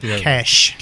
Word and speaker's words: cache: C-A-C-H-E, people. – Cash. It's actually pronounced cash.